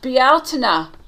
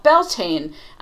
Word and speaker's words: Bealtaine: And it said, for the name of the month, it said Bealtaine, you know, which is the appropriate pronunciation of what other people call Beltane.